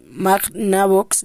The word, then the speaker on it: Magnavox: No fue hasta 1972 cuando se lanzó la primera videoconsola de sobremesa por la compañía electrónica Mega Navox. La Magnavox, lo dije mal, Odyssey fue creada por Ralph Baer, considerado como el padre de los videojuegos.